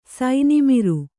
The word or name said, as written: ಸೈನಿಮಿರು